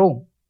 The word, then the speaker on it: Om: then what remains will be the sphota or Om. Therefore, Om is called the Nada Brahman or Sound Brahman. The three letters A, U and M, pronounced in combination as Om, are the generalized symbol of all possible sounds.